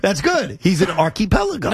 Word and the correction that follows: archipelago: No, it's not an archy. It's archipelago. – Archipelago. – Archipelago. – He's just emphasizing the wrong archie? Syllable.